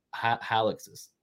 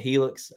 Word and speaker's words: Hilux: I've had multiple Tacomas, and I've always wanted to see one of these Toyota Hiluxes. Some people pronounce it Helix. I even said that in the video, but I got corrected. It's Hilux.